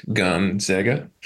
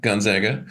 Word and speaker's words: Gonzaga: Gonzaga. – Yeah. – Zay, not Zag. Zaga, Gonzaga. Mm-hmm.